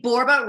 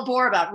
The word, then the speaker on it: Borba: I'm a 1L Michelle, and my last name, Borba, rhymes with Zorba. So there you go. Michelle Borba.